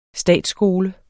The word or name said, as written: statsskole